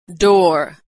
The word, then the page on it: door: American English